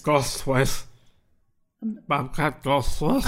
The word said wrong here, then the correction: Goldthwait: How do you say his name? – It's Bobcat Goldthwait. – Goldthwait? – That's right.